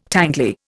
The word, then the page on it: tangly: Indian